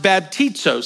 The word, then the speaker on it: baptizo: And the Greek word is pronounced baptizo, sounds just like it.